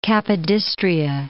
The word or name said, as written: Capodistria